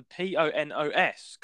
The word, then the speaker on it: Ponos: the P O N O S Corporation. Ponos.